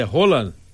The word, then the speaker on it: Haaland: Os caras dizem que a pronúncia não é mais Haaland, é Roland.